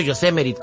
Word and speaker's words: Yosemite: Yosemite o Yosemite, ¿cómo se llama? – Yosemite.